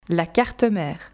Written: La carte mère

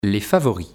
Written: Les favoris

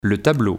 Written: le tableau

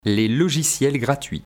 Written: les logiciels gratuits

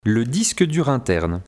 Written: Le disque dur interne

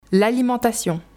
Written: L'alimentation

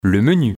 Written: le menu

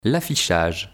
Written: l'affichage

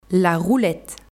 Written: la roulette

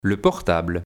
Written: Le portable

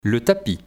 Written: le tapis

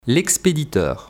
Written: l'expediteur

